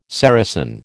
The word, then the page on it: Saracen: American English